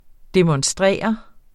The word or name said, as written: demonstrere